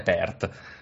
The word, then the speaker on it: Perth: A Perth. – Ah? – Perth. Perth? Se, se, sì, se vuoi, se vuoi la pronuncia più italianizzata è Perth.